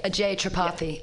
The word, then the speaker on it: Tripathi: Okay, Ajay Tripathi. Yeah. All right, I just have to put the emphasis on the other syllable.